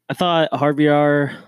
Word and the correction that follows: Javier Bardem: Javier, Javier Bardem.